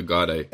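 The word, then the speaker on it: Gardaí: Am I saying that right? Guardia? It's like the Irish police force? – Guardia. I mean, Guardia. Just you can just say guards.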